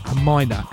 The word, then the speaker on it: Hmyna: Then we had, I think it's pronounced Hymina, H-M-Y-N-A.